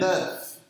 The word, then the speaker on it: neuf: How do you say Neuf. – It? – Neuf. – Neuf. – Neuf. – Neuf. – Neuf. Neuf. – Dix. Dix. Dig. What's ten? – Neuf. Dig. – No, that's Russian, bro. – Neuf.